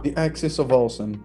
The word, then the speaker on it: The Axis of Awesome: Come si dice in inglese? Si pronuncia? – The Axis of Awesome. – Awesome, giusto, grazie per il tuo...